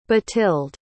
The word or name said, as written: bathilde